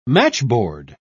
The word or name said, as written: matchboard